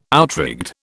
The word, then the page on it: outrigged: British English